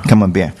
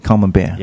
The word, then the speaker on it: Camembert: Camembert. Yeah. – Okay. – So the T, you see the T at the end is silent. So you Camembert.